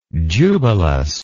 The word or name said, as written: jubilus